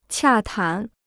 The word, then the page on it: 洽谈: qià tán